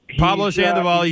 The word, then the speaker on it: Pablo Sandoval: It kind of reminds me of, and I hope I say this right, Paulo Sandoval. – Pablo Sandoval, you did say that right, but I don't.